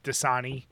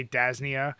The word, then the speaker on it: Dasani: Like, there's maybe a dozen words that I mispronounce, like Dasani. – Yes. – I used to say Dasnia.